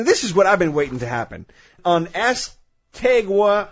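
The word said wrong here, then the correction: Assateague: how do you say that? Astetig Island. – Yeah, okay. Astetig.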